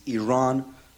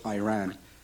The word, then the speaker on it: Iran: You say you support the Iranian people, but you're pronouncing our country's name wrong. Our country is named Iran, not Iran.